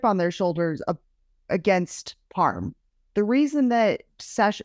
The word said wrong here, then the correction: Sachin: Sash oh my god, I just mispronounced say so badly. The reason that Sachin Sahel was in traction was because of Parm Singh Man.